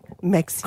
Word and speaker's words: Maxim: Well, Maxime last night, the magazine. – Is that how you say it? Maxime? – Maxime. – That's Maxime. – What do you say? It's Maxim.